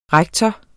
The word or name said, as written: rektor